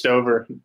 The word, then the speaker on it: Stoeber: No, it's Stoeber. No problem. Stoeber. Everyone butchers it.